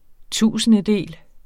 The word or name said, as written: TUSINDEDELE